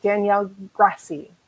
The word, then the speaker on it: Danielle Grassi: And I am joined, oh my goodness, I'm still getting goosebumps by your book, Danielle Grassi.